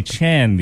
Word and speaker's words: Chen: I hear Chin, Chan. I was at an appointment last week. A woman reads my name off a list, pronounces it Chang. Chang? She reads it. Chang. No, ma'am. It's Stanley Chen. The G is silent and also invisible.